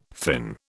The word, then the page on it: fin: British English